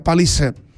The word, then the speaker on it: Palisade: Se escribe Palisade, se pronuncia Palisade en sus versiones Limited y Calligraphy.